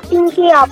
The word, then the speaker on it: Pinky Up: Se escribe Pinky Up. – Ajá. – Y se pronuncia Pinky Up.